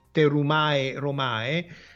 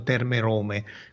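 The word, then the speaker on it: Thermae Romae: in katakana è, è Terumae Romae, perché appunto a quanto pare i latinisti dicono che in realtà il dittongo si pronunciasse così e non Terme Rome.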